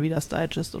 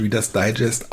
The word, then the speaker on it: Reader's Digest: Also in meiner Familie wurde es auf der ersten Silbe von Digest betont.